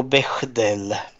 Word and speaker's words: Bechdel: Och sen kommer vi till eh, Be Bechtel-testet, eller hur uttalar man det? Bechtel-testet, ja. Eller, eller Be Be Bechdel, jag vet inte om det är något T. Nu ska vi se. Jo, Bechdel.